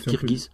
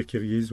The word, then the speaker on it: Kirghiz: Donc c'est dur à prononcer, il faut dire Kirghiz. – On dit Kirghiz, oui.